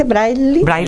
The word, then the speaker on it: Braille: Braille.